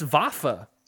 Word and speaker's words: Vafa: Venus Vafa.